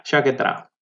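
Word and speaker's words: Sciacchetrà: How do you Chaquetra? Is that right? – Yeah, Chaquetra. – Okay, can you say the name of the wine so that we know exactly how to say it? – Yeah, his name is Chaquetra.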